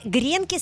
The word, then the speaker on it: гренки: Итак, я вам предлагаю сделать гренки с яйцом на сковороде. Кстати, я вам сразу скажу, правильно говорить по-русски «гренки».